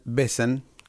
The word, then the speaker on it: Wesen: Soy cantante y guitarrista de la banda de hard rock industrial Besen, que se escribe W-E-S-E-N.